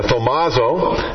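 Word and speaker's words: thaumazo: The word amazed here is thomazo, is how it's pronounced in the Greek.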